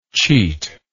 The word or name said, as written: cheatingly